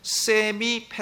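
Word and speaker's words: semi: 세미 펠라기우스 주의라고 합니다. 세미 하면은 절반이라는 뜻이에요. 발음할 때 세마이라고도 하고 하는데 세마이 펠라기우스 주의라고 부릅니다.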